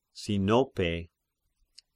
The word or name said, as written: Sinope